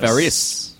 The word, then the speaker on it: Faris: Yeah, because that was a vehicle for Anna Faris. – Faris. – Yep. – I'm just now pronouncing her last name very specifically as well.